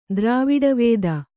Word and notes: ದ್ರವಿಡವೇದ: draviḍa vēda

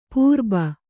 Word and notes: ಪುರ್ಬು: Kannada

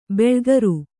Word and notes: ಬಿಳಿಗಾರ: biḷigāra

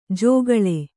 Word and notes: ಜಗಳ: jagaḷa

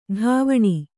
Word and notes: ಟವಣೆ: ṭavaṇe

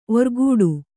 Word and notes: ಒರ್ಗೊಡೆ: orgoḍe

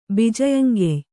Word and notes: ಬಿಜಯಂಗೆಯ್: kannada

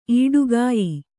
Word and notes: ಈಡುಗಾಯಿ: īḍugāyi

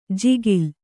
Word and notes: ಜಿಗಿಲ್: jigil

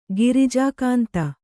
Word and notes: ಗಿರಿಜಾಕಾಂತ: girijā kānta

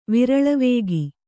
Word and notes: ವಿರಳವೇಗಿ: Kannada

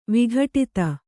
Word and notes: ವಿಘಟಿತ: vighaṭita

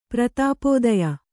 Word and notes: ಪ್ರತಿಪಾದ್ಯ: prati pādya

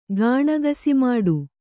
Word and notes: ಗಾಣಗಸಿಮಾಡು: Kannada

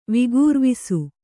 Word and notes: ವಿಗುರ್ವಿಸು: vigurvisu

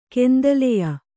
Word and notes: ಕೆಂದಲೆಯ: Kannada

